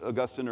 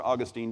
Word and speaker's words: Augustine: That's what Augustine or Augustine, depends how you like to pronounce it, says that, that the root of all sin is, is, is uh, pride.